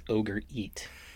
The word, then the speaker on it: orgeat: For those of you who don't remember, orgeat is also known as ogre eat.